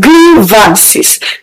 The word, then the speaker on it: grievances: Don't forget that the word in spelling and in pronunciation does not have I. So when you want to pronounce, you pronounce the word as grievances. Grievances. Not grievances. There is no high in the pronunciation. There is no high in the spelling. The word is simply pronounced grievance.